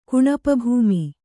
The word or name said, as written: ಕುಣಪಭೂಮಿ